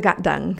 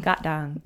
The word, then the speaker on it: Gatdang: To the Gatdang. – Gatdang. – Yeah. – When you read it, it looks like it's saying Gatdang. But when you said it, it sounds completely different.